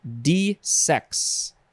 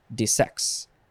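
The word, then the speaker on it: desex: This word is de-sex, and the emphasis is on the second syllable, de-sex.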